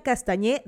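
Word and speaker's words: Castagné: Yo no sé si les he comentado esto, pero mi nombre completo es Giseli Yadira Castañé Domínguez, ok. Y Castañé no se escribe con ñ, se escribe con G-N-E que creo que lo han podido ver pues, por ahí de mi podcast, sale como que mi nombre.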